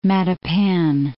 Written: Matapan

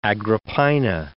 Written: Agrippina

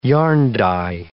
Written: yarn-dye

